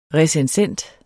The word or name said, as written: RECENSENTEN